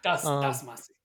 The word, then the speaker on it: Damacy: And so Katamari Damacy, I never say it right. I always add syllables. – Damacy.